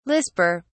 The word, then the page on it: lisper: British English